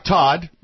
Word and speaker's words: Todd: You'd think, Chuck Todd, rhyming with God, would catch that.